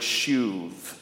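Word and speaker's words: shuv (שוב): You see, the word the Bible most often uses for repentance is the Hebrew word shuv. And I'm not mispronouncing that, by the way. In Hebrew, B's are often pronounced with a V sound, and so if you want to use the Hebrew version of my name, you can call me, okay?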